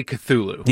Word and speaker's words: Cthulhu: I say Cthulhu.